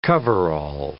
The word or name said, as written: cover-all